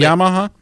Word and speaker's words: Yamaha: Sea-Doo, Can-Am, Beta, KTM, Polaris, and of course, is it pronounced Yamaha? – That's it. Nailed Yamaha.